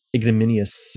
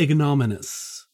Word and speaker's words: ignominious: Ignominious. – It's not ignominious. There's an I in there. Ignominious.